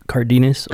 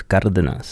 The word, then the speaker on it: Cardenas: Cardenas, or in Mexico, it's Cardenas.